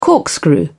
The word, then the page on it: corkscrew: British English